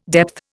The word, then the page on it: depth: Indian